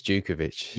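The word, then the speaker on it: Jutkiewicz: Janusovic, is that how you pronounce his name? – Lukas Jukovic. – That's him, yes. he's an absolute menace of a striker after.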